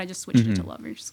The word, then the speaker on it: LVRS: So Lovers, for those of you listening, is spelled LVRS.